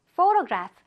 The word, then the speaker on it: photograph: photograph. 여기는 지금 ph가 앞에도 있고 맨 끝에도 있죠? 둘다 f, f 발음으로 해주면 돼요.